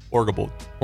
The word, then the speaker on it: Orgilbold: Orgilbold. – Okay. Those are, okay, exactly how you read them.